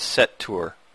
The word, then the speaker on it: Seether: He says, yeah, Sean, pronounced as set tour.